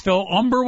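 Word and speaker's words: Humber: And remember, it's Phil Umber with the silent H in front.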